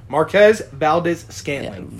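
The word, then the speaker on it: Valdes-Scantling: Valdez Scantling? – Yeah. Marquez Valdez Scantling.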